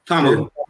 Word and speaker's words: Ta'amu: Do you pronounce it Ta'amu? Am I saying it right? – Ta'amu. – Ta'amu. Ta'amu.